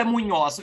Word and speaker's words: Munhoz: É Munhos ou é Munhoz? Eu ia falar Munhoz. – Munhoz, com a, com a Oxita, né? – Ah, tá.